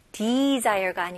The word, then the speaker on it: desire: 네, 여기 스펠링은 de이지만 di로 생각하시면 좋아요. desire가 아니고요. de, 그 다음에 s는 여기서는 z 발음이 나와야 돼요. 그래서 desire가 아니고요. desire. 해볼게요.